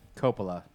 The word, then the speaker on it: Coppola: I'm pretty sure it's Coppola, not whatever the hell he was Coppola? – Pronouncing. Yeah, I'm pretty sure it's Francis Ford Coppola.